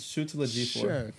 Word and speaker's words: Chiwetel Ejiofor: I'm Chiwetel Ejiofor. – It's Chiwetel Ejiofor.